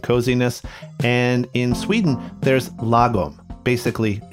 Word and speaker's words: lagom: Yeah, so it's pronounced lagom. You got it. You're pretty close.